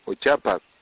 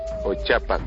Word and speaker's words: ochapac: Escuche bien la pronunciación dicha por una persona que habla el idioma malecu desde la infancia. – Adiós muchacha natoye curihul. Natoye curihul. Adiós muchacho natoye ochapac. Natoye ochapac.